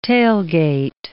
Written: tailgate

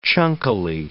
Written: chunkily

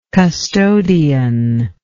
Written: custodianship

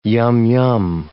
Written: yum-yum

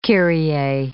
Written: kyrie

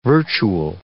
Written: virtual

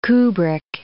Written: Kubrick

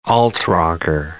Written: alt-rocker

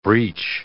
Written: breach

